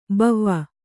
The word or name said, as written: ಭವ